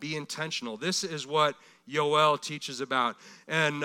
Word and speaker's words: Yoel: We would call him Joel. Pronounced accurately, Yoel.